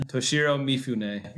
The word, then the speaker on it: Toshiro Mifune: You'd say Toshiro Mifune is probably fine for an American pronunciation. – Toshiro Mifune.